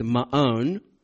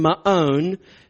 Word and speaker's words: Maon: Yes, again, that's the proper pronunciation. I know you guys think every time I pronounce it that way that I'm just setting myself up for a joke, but that's the way it's actually pronounced, Maon.